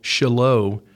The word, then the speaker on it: Shiloh: Shiloh.